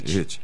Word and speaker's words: HIIT: Professor, eu tenho indicação médica para fazer um negócio chamado HIIT. É ritmo que se pronuncia. Hit.